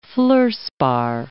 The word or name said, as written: fluorspar